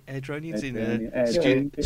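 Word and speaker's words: Airdrieonians: At the moment, Colin Cameron is the assistant manager at, I'm going to try and pronounce this, Airdronians. Is that right? Airdronians? Stu's giving me the official nod.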